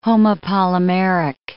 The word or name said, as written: homopolymeric